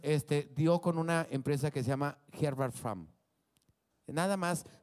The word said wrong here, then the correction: Herbert Farm: Herbert Fram así nomás, Farm, como de familia, Herbert Farm.